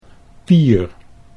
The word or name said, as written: Pier